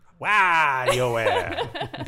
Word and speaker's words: WarioWare: You got to say it right. – I know. WarioWare.